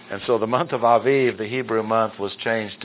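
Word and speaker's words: Aviv (אביב): On this day of the month, and A-B-I-B, is, the B's are pronounced as V's, Aviv.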